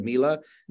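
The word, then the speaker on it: Milla: Mila? – Yes.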